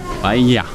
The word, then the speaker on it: paella: Nou, eh, dus eh, dubbel L is een medeklinker, extra medeklinker in het Spaans en dat wordt uitgesproken als een J. Dus in het alfabet is een L en een dubbel L en dat wordt uitgesproken als J. Dus paella. Paella.